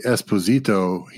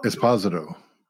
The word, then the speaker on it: Esposito: Yeah, but he does not do the Esposito. He does. Esposito. – Okay, so we had the wrong emphasis on the wrong syllable.